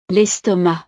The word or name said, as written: l'estomac